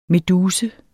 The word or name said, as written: meduse